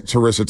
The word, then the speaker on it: Charissa: Yeah, him and Charissa, is it Charissa? – Charissa Thompson.